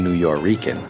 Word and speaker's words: Nuyorican: N-U-Y-O-R-I-C-A-N has become the established way of spelling it. So you have an almost phonetically Spanish way of saying New York-Rican.